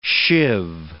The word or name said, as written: sheave